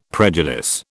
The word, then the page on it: prejudice: British English